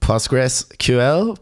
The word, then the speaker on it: PostgreSQL: Do you pronounce it PostgreSQL, PostgreSQL? – I tend to just say Postgres.